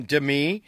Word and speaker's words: Demi: Do you remember a Seinfeld episode where they were arguing why she pronounces her name Demi? And George Costanza says, well, I've never heard of a semi-truck. Like semi-truck.